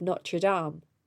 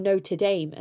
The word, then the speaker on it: Notre Dame: So he left the school of Notre Dame, or Notre Dame as Americans say, and went to work for the Mellon family.